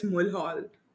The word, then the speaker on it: Mulhall: There was Mulhall. A very Scottish lady saying that it's Mulhall.